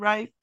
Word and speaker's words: Reif: Am I saying your name right, Christian Reif? – Yep, perfect. – Christian Reif, who is conducting El Nino Nativity Reconsidered for this performance.